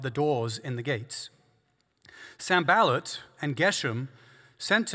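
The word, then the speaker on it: Sanballat: I've been saying, Sambalat's name wrong. It's Sambalat. So I'm going to try and fix that this week.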